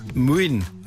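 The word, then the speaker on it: Młyn: Mün, also geschrieben M-L-Y-N.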